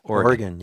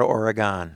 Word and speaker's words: Oregon: That's the correct pronunciation, you know. – Oregon, yes. Not Oregon.